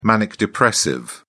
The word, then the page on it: manic depressive: British English